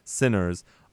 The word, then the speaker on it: SYNRS: They today as well, today, big day for announcements, they announced that their brand new track, Sinners, and you spell that S-Y-N-R-S, Sinners, is going to premiere on August 11th, 2016, obviously.